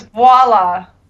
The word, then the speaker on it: voila: So the V is silent? – Yeah. – Oh, all right, go ahead. – Voila. Voila. Maybe it's like a half silent, half pronounced V. Voila. – You didn't use it at all. That's why I had to stop. I was like, whoa, did you just say voila without the V? – You know, like voila.